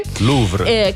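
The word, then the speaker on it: Kouvr: La canzone è dedicata a sua moglie, che si chiama Couvre, non so come si pronuncia. – Louvre. – Che lui conosceva già da giovanissimo. Sì, Louvre quasi, sì, la salvatrice, ecco.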